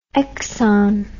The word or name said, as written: exxon